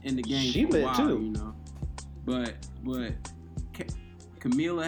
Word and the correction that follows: Kamala: I don't want to mess this up. Kamala.